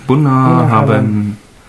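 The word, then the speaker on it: Bunnahabhain: Äh, die Distille, kann ich euch ja schon mal verraten, äh, es handelt sich um die Distille Bona Haven. Wie heißt die? Bona Haven. Buna, oh, das kann ich nicht aussprechen. – Buna Haven. Also das BH kann man wie ein V aussprechen.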